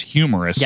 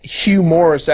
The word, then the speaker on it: Hugh Morris: It's not Hugh Morris, actually. It's humorous.